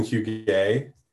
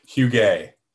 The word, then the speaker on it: Huguet: Huguet. – Huguet, okay. – U-E-T. – That's what I thought that you would not pronounce the T.